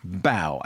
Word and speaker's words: Bao: With a wonderful short bow. I think that's how you pronounce it. – Yeah. – B-A-O. – Which is the name of a dumpling.